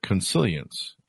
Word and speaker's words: consilience: Collective flow, panarchy, if I'm pronouncing that correctly, simultaneity, and consilience, if I'm pronouncing all those correctly. – Yes.